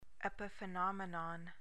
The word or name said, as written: epiphenomenon